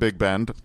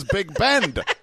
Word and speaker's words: Big Ben: So, look, I understand he could have been talking about Big Bend. I don't believe he was. I think he was specifically talking about how fans mobbed him at Big Ben, which he pronounces Big Bend.